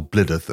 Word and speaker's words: Blidworth: I've got a place called Blidworth that I'm expected to call Blidderth, and there's a place called Leicester that people just call Leicester without even a fucking second blink.